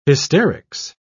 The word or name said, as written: hysterics